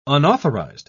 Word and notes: unauthorised: British English

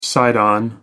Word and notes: side on: American English

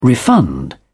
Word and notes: refund: British English

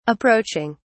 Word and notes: approaching: British English

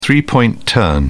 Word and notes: three point turn: British English

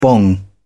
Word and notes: bong: British English

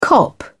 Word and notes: cop out: British English

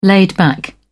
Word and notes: laid-back: British English

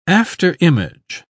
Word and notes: afterimage: British English

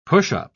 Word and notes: pushup: British English

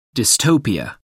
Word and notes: dystopia: American English

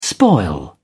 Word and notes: spoil: British English

